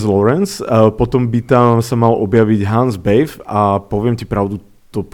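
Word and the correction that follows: Bethe: Aj som to tušil z toho, ako si to vyslovoval, bo typicky sa číta, že Bete. – Bete, ja aj nemecké.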